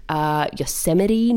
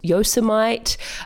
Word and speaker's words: Yosemite: Yosemite, not Yosemite.